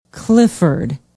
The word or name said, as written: Clifford